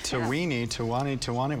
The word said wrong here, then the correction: Tatooine: What is it? – It's Tatooine.